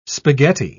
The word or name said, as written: spaghetti